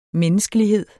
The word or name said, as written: menneskelighed